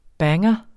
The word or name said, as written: banger